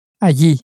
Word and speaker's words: allí: Now, the word for there is spelt A-L-L-I, which is why you've got the Y sound, because, of course, it's going back to the tortilla pronunciation rule. So you've got that Y.